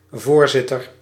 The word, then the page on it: voorzitter: /vo:ɾ.zɪ.tœɾ/